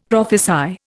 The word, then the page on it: prophesy: Indian